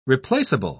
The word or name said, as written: replaceable